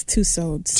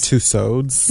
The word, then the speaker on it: Tussauds: Or, how do you say that in French? I'm pretty sure it's Tussauds. – Tussauds? – Yes.